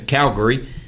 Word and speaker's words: Calgary: I think that's how they say it there. Calgary.